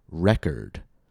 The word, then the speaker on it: record: For example, the word record, R-E-C-O-R-D, record. If I use R-E-C-O-R-D as a noun, I'm going to put the stress on the first syllable.